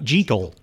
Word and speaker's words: Jekyll: And, of course, that Frederick March version, I think, was the only one which used the original Scottish pronunciation of Jekyll, Dr. Jekyll. – That's right. – And ever since then, it's... – Yes. – Ever since then, it's been Jekyll.